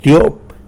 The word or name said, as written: tyop